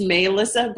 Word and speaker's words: Malissa: Did I pronounce your first name right? Is it Malissa or Malissa? Or I suck with this whole pronunciation thing. No, you don't, because that's what you do. You ask and that's how you don't suck. But no, technically it's Malissa, but my mom was like high on drugs when she had me.